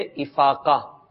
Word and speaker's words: افاقہ: افاقہ تو نہیں ہے کہیں، درست تلفظ ہے افاقہ۔ مجھے الحمدللہ بیماری سے افاقہ ہو گیا, یہ ٹھیک ہے افاقہ.